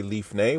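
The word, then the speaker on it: lifnay (לפני): And we use the word lifnay, which is a lamed in front of panay. So when you make lamed, pe, nun, yud, which is how you say lifnay, which is before, that literally is putting a lamed in front of the face.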